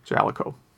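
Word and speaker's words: Jaleco: It does note that in some games, they do pronounce it at the boot screen. And it is pronounced Jalico.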